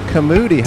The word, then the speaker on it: Kaumudi: Kamudi. How do you spell that? – K-A-U-M-U-D-I.